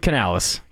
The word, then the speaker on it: Canales: Have you ever heard, is it pronounced Canales? – Dave Canales, correct.